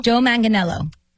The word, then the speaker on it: Manganiello: How do you say his name correctly? I've struggled with this for 10 years. – Me too. – Joe Manganiello. – Is that right? Joe Manganiello. Okay, Joe Manganiello and Sofia Vergara.